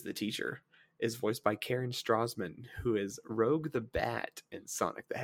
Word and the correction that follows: Rouge: Isn't that Rouge the Bat? – Is it pronounced Rouge? – I'm pretty sure it's supposed to be French. Like Rouge.